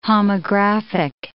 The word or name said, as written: homographic